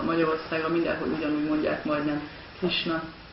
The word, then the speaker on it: Krishna: It's pronounced here the same in Hungary as it is in India, as it is in America, as it is in Russia. Krishna.